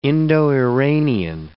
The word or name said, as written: Indo-Iranian